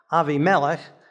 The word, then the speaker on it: Abimelech: So our topic this week is Abraham and Abimelech, or maybe better pronounced, Avimelech. And if we're going to pronounce Avimelech as Avimelech, maybe we should have been pronouncing Abraham as Avraham.